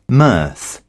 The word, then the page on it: mirth: British English